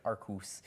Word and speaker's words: Arceus: How do you say Arceus? – Arceus? Okay. – I would say Arcus.